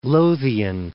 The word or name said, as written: West Lothian